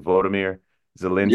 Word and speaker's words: Volodymyr Zelensky: And for people who don't really know who, am I pronouncing his name right? Volodymyr Zelensky? – Yeah, very good.